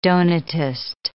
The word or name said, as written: Donatist